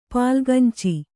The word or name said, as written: ಪಾಲ್ಗಂಚಿ